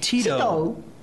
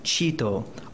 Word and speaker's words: Tito: In the original, the hunters named her Tito チトそんなことじゃないかと思っていました。 – That's what I had suspected. – 昭和30年代の古い訳音だから、その頃の日本の子供に T が発音できるわけない。それでもそれで育ちました。 – I'd read it in an old translation. Kids in Japan back then couldn't be pronouncing to it, couldn't be expected to pronounce the unfamiliar sound T, but that's the version I was brought up on. – コヨーテの名前はいつも私にはチトでした。私はチトでした。 – So for me, her name was always Chito.